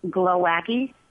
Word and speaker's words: Glowacki: Jamie, J-A-M-I-E, Glowacki, glow as in glow in the dark, wacky, W-A-C-K-I.